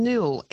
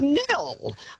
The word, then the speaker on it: Nhill: But look, Kate, also, our town, Nil, NHILL, and I get so much, I've just been to Melbourne and, you know, checked into hotels. They cannot pronounce the silent. They say N-Hill. Excuse me. It is Nil. – N-Hill. – In Hill, and you know, when they check you in, they say, "Oh, in Hill." No, Nil.